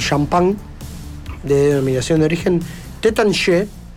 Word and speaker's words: Taittinger: Se lee Taittinger. – Se escribe. – En castellano, pero en francés la pronunciación es Tétanché. – Lo tengo yo. – Sí. – Sí, porque la E y la R no se pronuncian en francés.